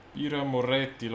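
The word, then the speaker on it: Birra Moretti: Bira Rometti. Bira Moretti, l'Authentica.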